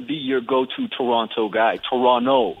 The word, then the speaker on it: Toronto: We don't pronounce the second T. – Hold the fuck on, man. Wait a second. You just threw me for a loop. I can't say Toronto. – Nope. No. The second T is silent.